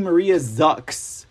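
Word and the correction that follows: Maria Zack: So thank you, people in the audience, for not uh, correcting and shaming me and making me feel stupid for saying her name wrong. I was like, Maria Zuck, Maria Zuck. I want to call her Maria Zuckerberg for some reason. Uh, Maria Zach.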